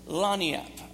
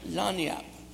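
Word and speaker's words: lagniappe: Okay, here's the word, Lagniap. Can you say that? Lagniap. Look it up. It's spelled like you would never believe. It's got G's and N's and all kind of French spellings in it.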